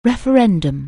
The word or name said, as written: referendum